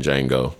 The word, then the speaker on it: Django: But to your point, Tim Scott's no different than Steven was in Django. The D is silent, or that Big Jim was, you know, in Huck Finn.